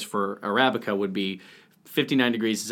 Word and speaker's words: Arabica: And because of that, it also has more caffeine than the Arabica.